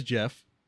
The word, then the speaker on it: Gef: But either way, its name is Jeff, and it's spelled G-E-F.